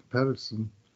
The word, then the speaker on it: Pettersson: But he's asked that it be pronounced Pettersson, the North American. He made a request.